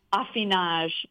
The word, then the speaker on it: affinage: A-F-F-I-N-E-U-R? – Yep, affineur practices affinage, which rhymes with massage,